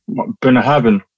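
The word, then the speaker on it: Bunnahabhain: Boona Harven. – Say it again. – Boona Harven. – Boona Harven. – No, you notice he put the V at the end.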